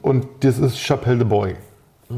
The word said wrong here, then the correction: Bois: Boy ist, glaube ich, falsch ausgesprochen. Äh, B-O-I spricht sich im Bois. Bois. Oh. Chapelle de Bois.